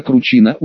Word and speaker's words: Кручина: Начальница паспортного стола милиционерша в мундире с погонами майора Ольга Ивановна Кручина, ударение на «и», а не на «у», о чем она меня сразу предупредила,